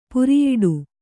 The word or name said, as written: ಪುರಿಯೋಡು